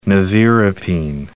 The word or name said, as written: nevirapine